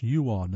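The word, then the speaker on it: Jawn: Yawn, and that's pronounced yuan, that's J-A-W-N.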